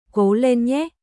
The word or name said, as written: Cố lên nhé